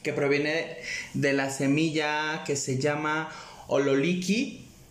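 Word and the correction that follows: ololiuqui: si lo pronuncie bien? – Ololiki. – perdón, ololiuki, que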